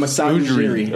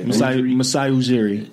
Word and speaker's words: Masai Ujiri: I'm Masai Ujiri. – Okay. – Masai Ujiri.